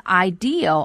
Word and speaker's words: ideal: 另外这个单词 ideal, I-D-E-A-L, 是来自于名词 idea, I-D-E-A, 表示的是理想的。在发音的时候大家一定要注意 ideal 这个词后面千万不要加上了 R, 读成了 idea, 这里没有 R, 后面是一个别音 L, 大家在读的时候呢可以适当的把这个前面的元音拖长一些。